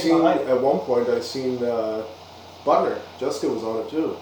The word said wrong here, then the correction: Bittner: Bittner. – Whatever. – It's pronounced Bittner now, but I got corrected at the Worlds.